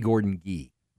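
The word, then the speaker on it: Gordon Gee: Fun fact, it's a hard G. It's E. Gordon G. Gordon G.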